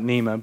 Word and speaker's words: Neema: It's spelled like N-E-E-M-A, so we would pronounce it Neema, but in Swahili you pronounce it Neema, and so we just kind of say it with our Texan accent, just Neema.